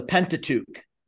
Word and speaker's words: Pentateuch: Oh, the Pentateuch.